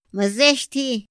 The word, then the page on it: ma zësthi: Otomí